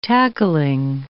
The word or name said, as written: tackling